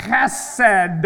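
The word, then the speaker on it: Chesed (חסד): This is the Hebrew word chesed. Say it with me, chesed. So you have to really kind of like gargle in the throat. Chesed.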